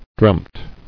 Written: Dreamt

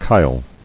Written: Chyle

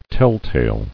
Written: Telltale

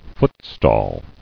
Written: Footstall

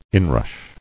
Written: Inrush

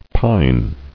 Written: Pined

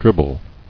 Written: Dribble

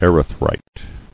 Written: Erythrite

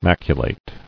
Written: Maculate

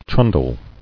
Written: Trundling

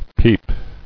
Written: Peeped

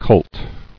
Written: Cult

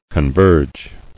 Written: Converge

